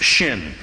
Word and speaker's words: Shin: If there's a dot over the left branch, it is pronounced seam. If it is over the right branch, it is pronounced shin.